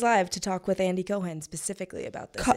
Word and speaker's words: Cohen: You have to start saying his name right if he's going to have you on. – I'm so sorry. – It's Cohen. – I love that you support him. – I know. It's not about him. It's not personal. He's Jewish. – Yeah. – You got to say Cohen, right? Also, look, you're working in entertainment. If you can't pronounce the word Cohen, it's not going to serve you well.